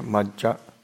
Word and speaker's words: Majja: So it's like two J's together, Majja, Pamadatana.